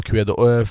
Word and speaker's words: Kuedo: Uttalar man det så, eller? – Ja, Kuedo.